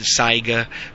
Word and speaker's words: Saiga: A Sega is one of them. Some people pronounce it Saiga. Some have different pronunciations for it. But look it up. S-A-I-G-A.